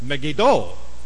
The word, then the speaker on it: Megiddo: But the one event that I remember very, very strongly took place at Megiddo, the Megiddo of Armageddon, but they pronounce it Megiddo. You must never say Megiddo. It's Megiddo.